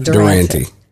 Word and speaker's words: Durante: Jimmy Durante. Is that how you pronounce it? – Durante. Yeah.